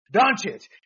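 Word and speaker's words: Dončić: Always Luka Doncic. – Doncic.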